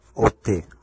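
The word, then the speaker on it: ot (אות): en hebreo ot, ote, así se pronuncia en hebreo ot.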